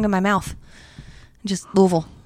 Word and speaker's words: Louisville: Louisville, yeah. Say it like you have a mouthful of shit. – Louisville. Louisville Slugger. – Yeah, that I'll say. – See? – Louisville.